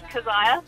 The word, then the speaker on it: Kezia: First of all, my name's pronounced Kezia.